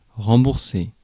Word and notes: rembourser: IPA: [ʁɑ̃.buʁ.se]; French; France

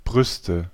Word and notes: sein: France; /'sɛ̃/

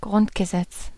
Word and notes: Grundgesetz: German

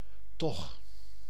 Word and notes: toch: /tɔx/

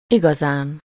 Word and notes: wirklich: /ˈvɪʁklɪç/; [ˈvɪɐ̯kʰlɪç]

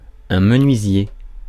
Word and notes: menuisier: [mə.nɥi.zje]

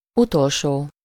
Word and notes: last: British English; /ˈlɑːst/; American English; /ˈlæst/

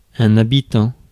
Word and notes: habitant: France; /a.bi.tɑ̃/

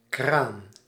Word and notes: tap: /tæp/

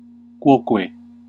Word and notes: quoque: Classical; /ˈkʷo.kʷe/

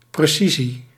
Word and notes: accuracy: American English; /ˈæk.jɚ.ə.si/

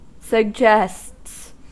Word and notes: suggests: American English

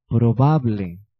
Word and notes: probable: [pʁɔ.ba.bl(ə)]; /pʁɔ.babl/

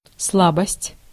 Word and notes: weakness: /ˈwiːk.nəs/

